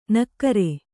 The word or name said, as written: ನಕ್ಕರಿ